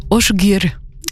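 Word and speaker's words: Ásgeir: Asgeir, A-S-G-E-I-R, semblerait qu'en fait ça se prononce Osgeir, un duo qui vient d'Islande entre Amy Margrét Sævarsdóttir et Osgeir Einarsson.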